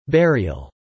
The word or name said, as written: burial